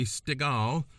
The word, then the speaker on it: Stegall: Okay, so I'm talking to State Trooper Katy Stegall, whose name rhymes with Steven Seagal.